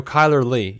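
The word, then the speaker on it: Chyler Leigh: How do you say her name? Kyler Lee? Is that What? – Kyler Lee. Is that how we pronounce her name? – Oh, Kyler Lee. Yeah, yeah.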